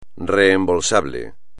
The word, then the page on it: reembolsable: espagnol